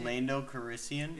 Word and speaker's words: Lando Calrissian: When Lando Calrissian, how do you say Lando Calrissian? – Yeah. – Fucking, you had it right.